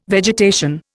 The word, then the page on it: vegetation: Indian